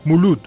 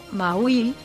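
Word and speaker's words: Mawlid: Entre los días 28 y 30 de octubre, los musulmanes conmemoran el Mawid al-Nabi, Mulud. – El término Mawil en pronunciación vulgar mulud, es el nombre que recibe la celebración del aniversario del nacimiento del profeta Mohamed.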